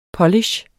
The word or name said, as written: polish